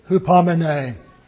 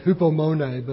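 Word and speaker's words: hupomene (ὑπομονή): Well, the word for perseverance is the Greek word hupomene. Some pronounce it hupomone, but hupomene.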